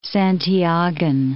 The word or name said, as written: Santiagan